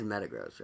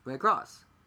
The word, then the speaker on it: Metagross: We now know that it is Metagross, right? That's how you say it. Metagross. I got it right. – Metagross. Yeah.